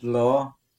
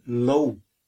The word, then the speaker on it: leo: Now, L-E-O is pronounced law in the Ulster dialect. Other places they pronounce it low, like low. But up here we say law.